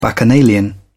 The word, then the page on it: bacchanalian: British English